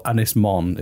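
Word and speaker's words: Ynys Môn: Anglesey or Ynys Mon is the Welsh. – Ynys Mon.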